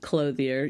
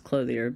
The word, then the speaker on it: Clothier: So first your name's Suzanne, and secondly, it's Clothier. But other than that, are you really a close friend? No. So just Clothier, just the good old-fashioned English pronunciation.